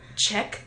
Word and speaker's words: Czech: Czech sex room. Like, check, like, CZ.